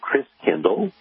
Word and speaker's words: Christkindl: And the German pronunciation of Christ child is Christkindl, like kindergarten, kindercare. Kindle means child. And so Christkindl ended up being pronounced Kris Kringle.